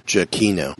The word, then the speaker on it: Giacchino: So if you're following either one of those on Twitter, And I'm gonna say, according to his Twitter, his last name is pronounced Giacchino. – Giacchino? – Giacchino, not Giacchino. Giacchino.